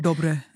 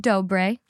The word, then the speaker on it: Dobre: Dobre.